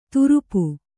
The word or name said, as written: ತುರುಪು